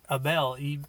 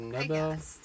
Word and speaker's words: Abel: How do you spell that name? – Abel. – A-B-E-L. – Okay. I thought I heard Nebel.